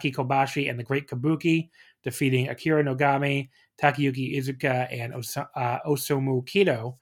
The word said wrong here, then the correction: Osamu Kido: in 13-12. I totally butchered that. in 1312. – I commend you for trying. – Osamu Kido is what I should have said in 1312.